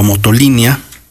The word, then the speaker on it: Motolinía: Ok, se cuenta que una vez establecida la comunidad en El Alto, el conocido barrio del Alto, Fray Toribio de Benavente, más conocido como Motolinía o Motolinía, como parece ser es la verdadera pronunciación.